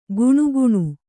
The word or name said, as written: ಕುಣುಕುಣು